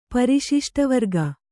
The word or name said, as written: ಪರಿಶಿಷ್ಟವರ್ಗ